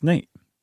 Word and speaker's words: Nat: Yeah, it's Life and Adventures of Nat Love, and it's spelled N-A-T in the autobiography title. – I swear it's Nate. – Well, I'm looking at the book cover. – I know. I'm telling you, it's pronounced Nate. – Okay, but there is no E.